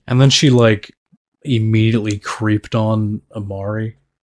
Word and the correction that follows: Amira: Why can you not say Amira? – Oh, am I saying it wrong? – It's Amira. – Am I pronouncing that right? – Amira, not Amari.